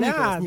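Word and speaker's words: Nicholas: Так вот, начиналось-то все на самом деле не так уж погано-то для дебютанта будущего сезона в команде Вильямс э, Николя Летифи. – Давай называют только не ну, Николя, ну, Николас, Николас, да, он да. все-таки Николас, и он даже, по-моему, произносит Латифи.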